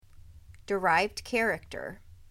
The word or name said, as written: Derived character